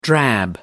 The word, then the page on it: drab: American English